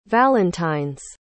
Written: valentine's

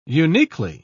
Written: uniquely

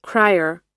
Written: cryer

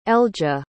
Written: eljah

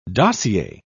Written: dossier

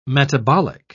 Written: metabolic

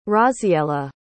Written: Raziela

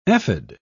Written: ephod

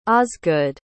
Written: osgood

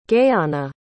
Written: Gaiana

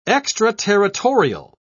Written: extraterritorial

